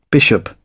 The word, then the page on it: bishop: British English